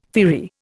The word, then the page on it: firry: Indian